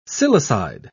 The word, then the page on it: silicide: British English